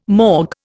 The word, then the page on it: morgue: Indian